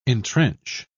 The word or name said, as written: entrench